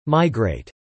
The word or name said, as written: migrate